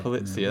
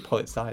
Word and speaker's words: Polizia: So a bit of Italian how do you pronounce it? Like Polizia. – Polizia. That's it. I always want to say Polizei. It's German.